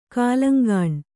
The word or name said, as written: ಕುಲಾಂಗನೆ